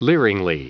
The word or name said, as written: leeringly